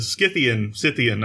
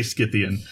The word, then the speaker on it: Scythian: But yeah, the Scythian, I'm never sure whether you pronounce the C as a K or not. – I swear that we've looked this up before and you could do it either way and the only difference is whether it's British English versus American English.